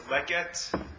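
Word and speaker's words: leket (לקט): In Hebrew, we call those gifts leket, shechacha, and peah, the three main ones. Leket, in English, I'd say L-E-K-E-T. Shechacha, in English, I would say is S-H-I-C-H-E-C-H-A or something.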